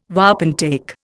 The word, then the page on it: wapentake: Indian